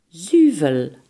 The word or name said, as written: zuivel